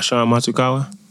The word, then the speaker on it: Sean Matsukawa: How do you say his name? – Oh, Sean Matsukawa. – There you go.